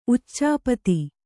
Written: ಉಚಾಪತಿ

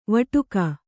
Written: ವಟಕ

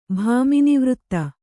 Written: ಭಾಮಿನಿವೃತ್ತ

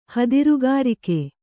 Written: ಹದಿರುಗಾರಿಕೆ

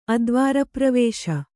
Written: ಅದ್ವಾರಪ್ರವೇಶ

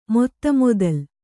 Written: ಮೊತ್ತಮೊದಲು

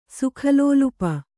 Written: ಸುಖಲೋಲುಪ

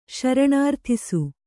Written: ಶರಣಾರ್ಥಿಸು

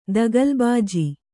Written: ದಗಲಬಾಜಿ

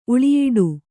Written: ಉಳಿಯಿಡು

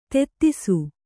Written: ತೆತ್ತಿಸು

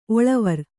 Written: ಒಳವರಿ

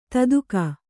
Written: ದಾಧಿಕ

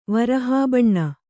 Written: ವರಹಾಬಣ್ಣ

